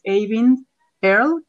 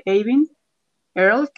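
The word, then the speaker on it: Eyvind Earle: Em, uno de ellos es Avin Earl, que se escribe E-Y-V-I-N-D, Avin Earl,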